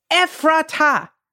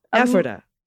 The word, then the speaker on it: Ephrata: It's Ephrata. – Ephrata. – Emphasis on emphasis on the first syllable. – Ephrata.